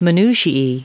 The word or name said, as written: minutiae